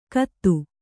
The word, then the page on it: ಕತ್ತು: Kannada